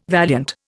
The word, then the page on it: valiant: Indian